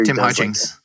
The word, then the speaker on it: Hutchings: I think it was Tim Hitchings. Is that how you say his name? The TV commentator. Tim Hutchings.